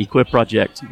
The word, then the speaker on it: Equip Project: it would look like it's pronounced Equip Project, but I don't know if I've got it right. Help us out. – That's basically what it sounds exactly right. Equip Project, run on word.